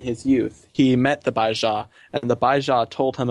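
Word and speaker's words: Bai Ze: The Baijia. That's how you'd pronounce it.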